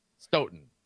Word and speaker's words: Stoughton: I just, you know, Stout, you know, when in doubt, go to Stout. We're lacrosse guys. Okay. Stoughton, right? Stoughton. There you go. – Yeah, Plumber. Well, Stout doesn't have a GH in there.